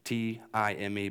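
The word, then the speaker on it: time (τιμή): This word honor is a Greek word that is the word time, T I M E, but it's pronounced time.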